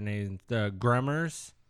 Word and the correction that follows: Gamorreans: Gamorreans. – Yeah. Gamorreans.